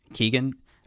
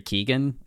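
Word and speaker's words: Keoghan: how do we say his last name again? – Keegan. – Barry Keegan.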